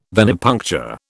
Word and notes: venipuncture: British English

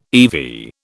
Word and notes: eV: British English

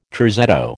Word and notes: terzetto: American English

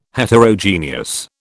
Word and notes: Heterogeneous: British English

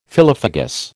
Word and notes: phyllophagous: American English

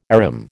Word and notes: arum: American English

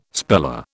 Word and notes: speller: British English

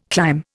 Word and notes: climb: Indian

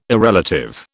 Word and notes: irrelative: British English

